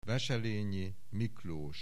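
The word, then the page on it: Wesselényi, Miklós: Hungarian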